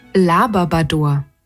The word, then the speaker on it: Labrador: Labrador.